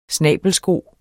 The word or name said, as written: snabelsko